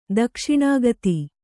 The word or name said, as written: ದಕ್ಷಿಣಾಗತಿ